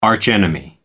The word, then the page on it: arch enemy: American English